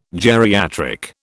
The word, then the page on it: geriatric: British English